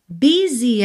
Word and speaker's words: busy: Busy, essa palavra que se escreve B-U-S-Y, a pronúncia é busy.